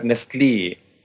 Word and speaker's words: Nestlé: Und die spricht dann halt die ganzen Lehnwörter oder die ganzen Eigennamen so aus, wie die Schweizer aussprechen, nämlich korrekt. Mhm. So die Firma, diese, die diese Puddings herstellt und so, die heißt halt Nestlé.